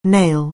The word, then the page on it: nail: anglais